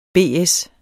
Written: BS

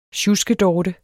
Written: SJUSKEDORTES

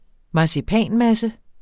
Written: marcipanmasse

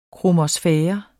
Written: kromosfære

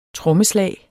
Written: trommeslag

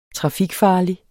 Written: trafikfarlig

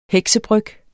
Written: heksebryg

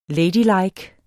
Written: ladylike

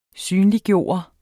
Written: synliggøre

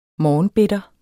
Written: morgenbitter

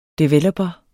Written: DEVELOPERE

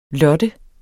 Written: lotte

